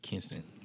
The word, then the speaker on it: Kinston: Kinston.